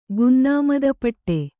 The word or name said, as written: ಗುನ್ನಾಮದಪಟ್ಟೆ